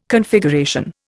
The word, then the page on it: Configuration: Indian